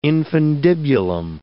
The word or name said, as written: infundibulum